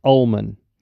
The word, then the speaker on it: Uelmen: It's spelled U-E-L-M-E-N, but it's pronounced Ullman.